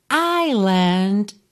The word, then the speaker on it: island: Porque se escreve I-S-L-I-N-D. Mas esse S é completamente mudo. Fica island. Nem traço do S, tá?